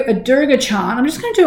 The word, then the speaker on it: Durga: And if you're wondering why I say Durga, it's because I learned about this in India, and my teacher said it's very important to pronounce Durga's name properly. So I don't say, normally I would have said Durga because it's spelled D-U-R-G-A, but I was told, give her the respect that she's due, goddess is due, and pronounce her name properly, Durga.